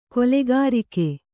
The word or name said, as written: ಕಲಗರಿಕೆ